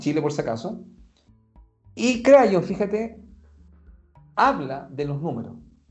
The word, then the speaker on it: Kryon: Ya, Crayon, que se escribe K-R-Y-O-N, esta es una entidad,